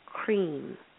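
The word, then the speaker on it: Krim: Then the next one, these are the ones that we will be doing today, is cream, and that's K-R-I-M, pronounced cream, like creamer, but with a long E.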